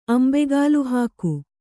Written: ಅಂಬೆಗಾಲುಹಾಕು

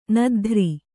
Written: ನದ್ಧ್ರಿ

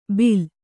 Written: ಬಿಲ

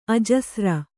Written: ಅಜಸ್ರ

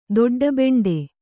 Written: ದೊಡ್ಡಬೆಂಡೆ

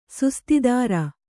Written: ಸುಸ್ತಿದಾರ

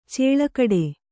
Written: ಛಲಗೇಡಿ

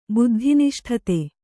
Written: ಬುದ್ಧಿನಿಷ್ಠತೆ

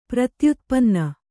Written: ಪ್ರತ್ಯುತ್ಪನ್ನ

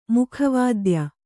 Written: ಮುಖವಾದ್ಯ

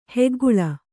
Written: ಹೆಗ್ಗುಳ್ಳ

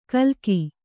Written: ಗಿಲಕಿ

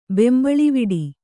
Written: ಬೆಂಬಳಿವಿಡಿ

